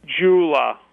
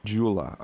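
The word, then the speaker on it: Dyula: It's pronounced weirdly because of the spelling and all that. – That's that whole New World Order thing. Doula? – Jula. – Jula, okay.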